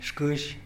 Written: xkïzh

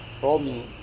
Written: romi

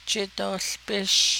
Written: jidoołbish

